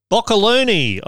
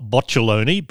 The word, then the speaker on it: Boccalone: Uh, it was called Boccoloni. I assume that's pronounced not boccialoni, B O C C A L O N E, because boccialoni would sound a bit too much like botulism.